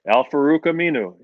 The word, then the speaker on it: Al-Farouq Aminu: Al-Farouq Aminu.